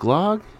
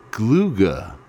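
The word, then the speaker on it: glögg: Oh no, that's not pronounced glog. It's pronounced gluga.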